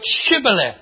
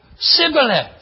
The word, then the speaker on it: Shibboleth: And the scripture says that the people of Ephraimite, these isolationists, could not say the word Shibboleth. They could only say Sibboleth. That's significant. What they were unable to do was pronounce clearly Shibboleth. When they tried to pronounce clearly Shibboleth, all that came out was Sibboleth.